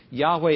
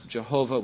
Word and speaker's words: Yahweh: Yahweh is the Old Testament name for God. Sometimes you hear it pronounced Jehovah, which isn't as accurate. And in many Bibles, it's written in all caps. It'll be L-O-R-D, all in capital letters, or G-O-D, all in capital letters.